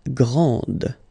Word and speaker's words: grande: This final D is not pronounced. Okay, so grand, and then the feminine form, grande. Okay, so you put this E, and then you get the sound D, D, grande.